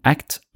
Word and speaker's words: ACT: ACT står for Acceptance and Commitment Therapy, og uttales som det engelske ordet ACT.